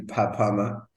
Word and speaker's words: Papama: And in my final year of studies, I started an NGO called Papama, which is spelled Fafama, but it's actually Papama.